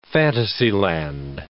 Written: fantasyland

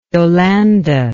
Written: Yolande

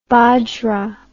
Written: bajra

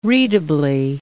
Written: readably

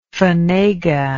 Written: fanega

